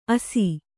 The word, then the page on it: ಅಸಿ: asi